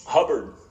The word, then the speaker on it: Hubbard: Hubbard. – How do you spell that? – H-U-B-B-A-R-D.